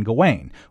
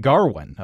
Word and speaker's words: Gawain: This is Lowery's adaptation of the 14th century chivalric romance Sir Gawain and the Green Knight, with Dev Patel playing Gawain, or Gawain if you prefer, or even Garwin, a pronunciation the actor playing King Arthur brought to the table, and that Lowery decided to roll with, nodding to the fact that there are already multiple interpretations of the name and of the original romance itself.